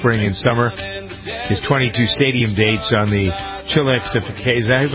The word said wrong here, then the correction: Chillaxification: I thought I pronounced it right the first time. – Chillaxification. – Chillaxification. Okay. – I'd just like to hear you say it. That's more fun. – Chillaxification, yes.